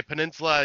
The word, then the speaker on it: Peninsula: Am I pronouncing it wrong? – You're probably pronouncing it fine. – Yeah, I'll say Peninsula. Yeah. – Peninsula. What am I saying? Pendula.